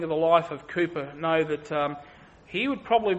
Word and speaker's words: Cowper: When the subject of joy comes up, often people will reflect upon the life of a man like William Cowper. It's pronounced Cooper, spelt Cowper.